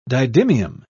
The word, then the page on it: didymium: British English